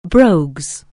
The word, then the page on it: brogues: anglais